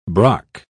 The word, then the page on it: brock: British English